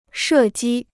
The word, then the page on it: 射击: shè jī